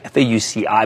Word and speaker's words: Fauci: That's F A U C H C I, F A U C I, but it's pronounced Fauci.